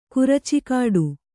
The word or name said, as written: ಕುರಚಿಗಿಡ